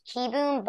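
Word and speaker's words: Kieboom: think it's probably I'm pretty sure it's pronounced Kiboom, but it's fun to Yeah.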